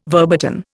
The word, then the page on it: verboten: Indian